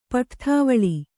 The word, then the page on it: ಪಠ್ಠಾವಳಿ: paṭhṭhāvaḷi